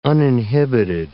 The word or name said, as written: uninhibited